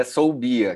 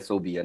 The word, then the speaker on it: Soubhia: Cara, muita gente erra, mas é Soubia, quer dizer, a pronúncia brasileira é Soubia, né? pronúncia em árabe, aí você não me pergunte, que eu também não sei.